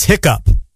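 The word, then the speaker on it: hiccough: Even though it ends in O-U-G-H, it's pronounced like hiccup.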